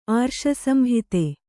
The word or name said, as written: ಆರ್ಷಸಂಹಿತೆ